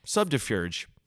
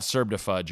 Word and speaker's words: subterfuge: Subterfuge. That's how you say it. – Okay. – Subterfuge. – So yeah, it's not Serbdefudge or whatever I said before.